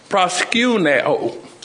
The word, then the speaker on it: proskuneo: The first word is pronounced proskuneo.